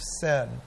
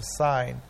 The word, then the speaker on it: Sin: where they were in the wilderness of sin at Mount Sinai. You could pronounce it wilderness of sign, but it just fits so well, the wilderness of sin, Mount Sinai.